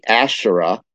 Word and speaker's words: Asherah: Now that's that word I can pronounce correctly and spell it right. The Asherah, which is a term that's in the Old Testament, A-S-H-E-R-A-H.